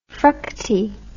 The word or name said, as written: fructi